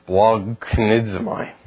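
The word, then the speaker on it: Splognidzmai (σπλαγχνίζομαι): This is such a great Greek word because it starts with the consonants like S, P, and L. And then it has one vowel, and then it has the English equivalent of G, K, N. S, P, L, A, G, K, N. You try to pronounce that. Splognidzmai.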